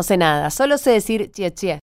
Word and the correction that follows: Xie xie: y por ahí lo pronuncio mal, que es gracias, ¿no? – Xie xie.